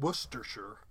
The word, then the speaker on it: Worcestershire: And in Worcestershire, the C-E-S in the middle kind of naturally disappears, leaving us with Worcestershire like that. – Okay, we drop the R. So instead of Worcestershire, it's Worcestershire.